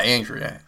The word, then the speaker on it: Andrea Arnold: And this is from director Andrea Arnold. Is that how you say her name? Andrea Arnold. Or Andrea? Yeah, Andrea. Andrea Arnold, who's directed, I believe this might have been her first documentary.